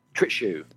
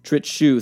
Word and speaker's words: Trittschuh: And your coach is it Tritcha? Is that how you pronounce that? Trittschuh, Trittschuh, thank you.